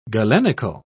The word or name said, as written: galenical